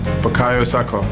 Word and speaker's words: Bukayo Saka: Bukayo Saka.